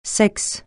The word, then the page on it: sexe: francais